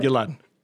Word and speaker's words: Gilad: Gilad.